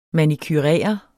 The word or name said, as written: manicurere